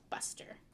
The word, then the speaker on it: Buster: Well, when I say Busker, I was saying it wrong, because his name is Buster.